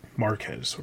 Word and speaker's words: Marquez: But the new series is going to be written by Joshua Williamson and the art is by David McQuez. I'm probably pronouncing that wrong. He comes from Marvel, has been working on Marvel stuff for quite some time. – Marquez.